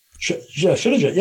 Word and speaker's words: Shilajit: What do you think about, really quickly, before you talk about the nanoparticle minerals, what do you think of Shilajit? I don't even know if I'm saying that right. – Shilajit, yeah, it's great.